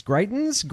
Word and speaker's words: Greitens: Missouri Governor Eric, how do you pronounce this? Greitens? G-R-E-I-T-E-N-S. Greitens, I guess? – Greitens?